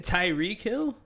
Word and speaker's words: Tyreek Hill: I wanted Tariq Hill like a motherfucker. He took him. – Isn't it Tyreek Hill? – I don't care. Tyreek.